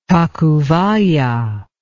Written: tacubaya

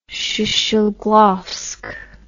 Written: shcheglovsk

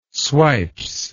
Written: swipes

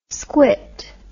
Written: squit

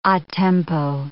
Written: a tempo

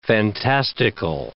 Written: fantastical

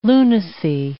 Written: lunacy

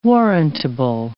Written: warrantable

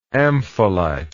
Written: ampholyte

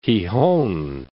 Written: Gijon

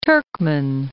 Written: Turkman

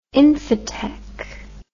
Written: infotech

